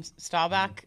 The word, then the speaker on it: Staubach: No free ads, but somebody at Under Armour sent me this awesome Staubach.